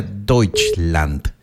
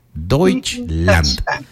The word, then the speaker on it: Deutschland: Pero si sí le interesa, se pronuncia Deutschland. Deutschland.